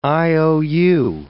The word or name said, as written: IOU